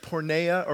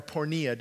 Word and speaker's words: porneia: We get the word actually pornography, I don't know if you know, from a word porneia or pornea, depending on how you want to pronounce it.